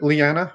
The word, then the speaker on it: Liana: Is it Liana? Are we pronouncing that? Yeah.